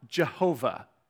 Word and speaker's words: Jehovah: And you either pronounce this Yahweh or Jehovah, depending on where the vowels go. And so Yahweh, Jehovah, you've heard both those. Those are just different pronunciations for the same word, which is this word right here, okay?